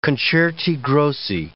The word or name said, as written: concerti grossi